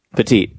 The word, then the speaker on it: Petit: I think they just really want Petit or Pettit. Is it Petit or Pettit? – Petit. – Yeah. – That is awesome. I got the name right.